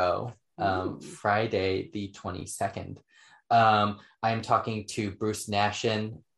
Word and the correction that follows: Naxon: Naxon. I always mispronounce his last name. Bruce Naxon.